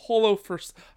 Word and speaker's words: Holofcener: Nomination three is The Last Duel by Nicole Holofcener. I don't know how to say this name. Can you help me out here? – Holofcener? – Sure.